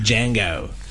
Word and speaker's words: Django: Es Django. Se escribe con D y en la película dicen la D no se pronuncia.